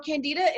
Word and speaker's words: candida: But what I wanted to ask you is, what, I've never heard of the word candida. Is it, am I saying it right? What does that mean? – Yeah. So candida is, it's a bacteria overgrowth.